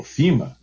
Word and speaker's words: FEMA: Mas o poder público, há um órgão federal aqui chamado FEMA, ou FIMA, na pronúncia americana,